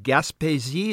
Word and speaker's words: Gaspésie: Well, I want to get back to your first single, which I believe was Gaspésie.